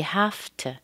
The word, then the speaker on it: have to: Now have to is pronounced kind of like H-A-F-T, schwa. That's what it sounds like. You have to.